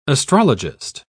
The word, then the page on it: astrologist: British English